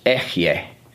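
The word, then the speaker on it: Echye (אֶהְיֶה): I am is pronounced Echye, and that is the first person singular of the verb to be.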